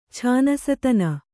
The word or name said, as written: ಛಾನಸತನ